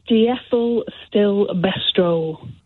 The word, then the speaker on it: diethylstilbestrol: How do you pronounce this? – Hi, Nick. Thanks for having me on the show. – Not at all. – It's diethylstilbestrol.